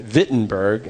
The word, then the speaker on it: Wittenberg: And I want you to transport yourself in your mind to a medieval village, to a muddy town square in Wittenberg. It was spelled with a W, but pronounced with like a V, because they can't pronounce their W's in Germany.